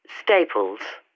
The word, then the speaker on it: Staples: Staples. – And how do you spell that? – S-T-A-P-L-E-S.